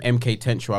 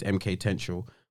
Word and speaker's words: MK-Tential: I'm gonna make sure I'm saying his name correctly. I do believe it is MK Tenshu. Because I know you're obviously doing our list, so you know I can do the business later. I just want to make sure that I've pronounced his name correctly. – If any of you lot are overseas listeners and that. – Yes, I was right. – He was right. – So MK-Tential, T-E-N-T-I-A-L.